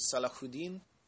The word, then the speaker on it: Saladin: Philip would return to France, whereas Richard would continue to fight the great Muslim Sultan Saladin, pronounced correctly as Salahuddin.